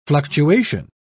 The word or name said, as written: fluctuation